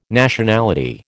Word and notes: Nationality: American English

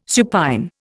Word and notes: supine: Indian

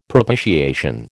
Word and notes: propitiation: American English

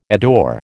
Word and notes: adore: American English

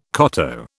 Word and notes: koto: British English

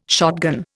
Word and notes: shotgun: Indian

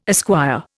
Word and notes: esquire: Indian

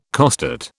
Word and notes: costate: British English

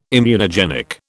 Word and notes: immunogenic: British English

